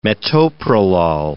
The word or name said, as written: metoprolol